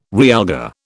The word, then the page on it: realgar: British English